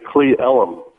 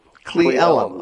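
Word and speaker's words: Cle Elum: It's Clea Ellum. – Clea Ellum.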